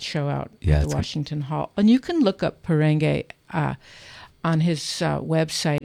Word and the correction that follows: Porangi: And Perenge is P-O-R-A-N-G-U-I. – Yeah, Porangi. – Porangi. – Porangi. – I've been saying it wrong all the time.